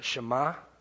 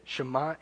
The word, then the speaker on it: Shema: The word there for listen, that's all over the Bible in the Hebrew, is Shema, I believe is how you say it. Shema, and it means to listen.